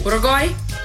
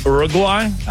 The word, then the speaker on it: Uruguay: Well, it doesn't start with a your sound. – Oh. – Uruguay? – Uruguay.